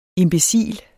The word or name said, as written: imbecil